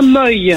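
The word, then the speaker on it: Moye: On prononce comment le nom de ta ville? – Donc c'est bien Moy.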